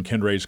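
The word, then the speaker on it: Kendre: Kendry.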